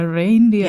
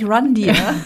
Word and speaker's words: Reindeer: Äh, ja, Reindeer. – Der heißt noch halt auch nicht Rundeer.